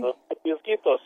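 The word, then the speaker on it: Los Peñasquitos: I was wondering, what's a microclimate that you'd find in Los Pianosquitos? Did I even come close to pronouncing that correctly? – Los Pianosquitos, you did.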